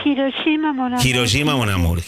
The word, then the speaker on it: Hiroshima Mon Amour: Hiroshima Mon Amour. – Hiroshima Mon Amour.